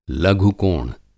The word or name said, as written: लघु-कोण